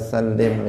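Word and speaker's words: salli (صلِّ): Nah, juga harus dibaca shot, jangan dibaca sin. Jangan mengatakan Allahumma salli Ah, salli wa sallim misalnya tetapi dia harus membacanya salli pakai sod karena maknanya juga akan berubah hadirin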